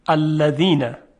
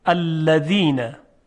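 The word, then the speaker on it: الذين: مثلا در کلمه الذین که در نماز هم داریم، الذین، خب دقت کنید، الذین، ذی، ذی، میبینیم که سر زبان به سر دندانهای صنایای بالا برخورد میکنه و صدای زال ایجاد میشه.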